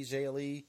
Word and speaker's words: Zhao Li: They had a women's match next, Vanessa Bourne and Aaliyah, I guess is her name, versus Tenera Conti and Zhao Li, or I think that's how you say her name, Zhao Li. – Zhao Li, yeah.